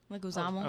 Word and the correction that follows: Leguizamo: Oh, oh, yeah, Leguizamo.